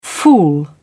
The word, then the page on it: fool: British English